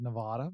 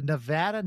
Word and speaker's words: Nevada: Darwin Morgan was the public information officer for NNSA out at the Nevada. I mispronounced it and said Nevada, but it's the Nevada National Security Site for 3 decades, and he is now a historian that gives tours out at the site.